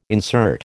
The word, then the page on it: insert: American English